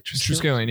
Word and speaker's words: Triskelion: I understand why you're in the Triskelion, right? Is that how you say it? – Triskelion, yeah.